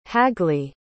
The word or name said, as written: hagley